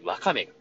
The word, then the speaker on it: ワカメ: 正しくは和にアクセントをつけて、ワカメが、えー、正しいアクセントだ